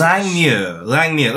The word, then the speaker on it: Langmuir: Moyer is M-O-I-R. This is M-U-I-R. So you're right, Alice. Jan Moyer. It'll be... I don't. – I think it's Muir. Scottish. – Langmuir. Let's go with Alice's pronunciation of Irving Langmuir.